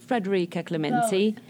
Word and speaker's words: Federika Clementi: Should I pronounce it properly or understandably? Usually I just go with Federika Clementi.